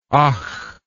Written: ach

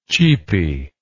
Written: cheapy

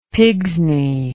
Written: pigsney

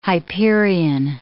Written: Hyperion

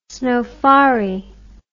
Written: snofari

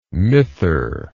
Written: mither

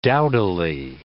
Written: dowdily

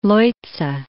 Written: Leutze

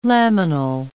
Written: laminal

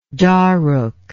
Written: dharuk

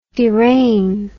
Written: deraignment

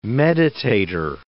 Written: meditator

